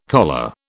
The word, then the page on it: choler: British English